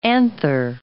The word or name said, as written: anther